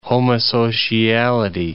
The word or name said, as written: homosociality